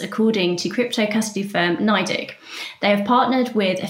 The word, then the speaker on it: NYDIG: says crypto custody firm NYDIG. How am I pronouncing that? Is that right? – NYDIG. – NYDIG. Oh, NYDIG.